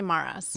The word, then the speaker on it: Maras: I just say Maras. – Maras. Okay. – Yeah. But I mean, you're really supposed to roll the R, but I don't. I myself don't even do that, so how could I ask anyone else to?